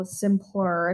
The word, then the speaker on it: Symplur: There's a website that I learned about called Symplur.